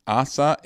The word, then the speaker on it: Asa: Asa.